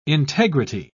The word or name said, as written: integrity